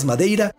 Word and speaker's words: Madeira: En 1926, antes de que el Benfica fuera campeón, el Marítimo de Islas Madeira, o por pronunciarlo a la portuguesa, el Marítimo de Islas Madeira, ya había ganado su primer campeonato de Portugal.